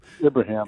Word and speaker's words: Ibrahim: One, Muhammad Ibrahim. I hope I'm pronouncing that right, Ibrahim. – Ibrahim, yeah.